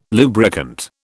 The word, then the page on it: lubricant: British English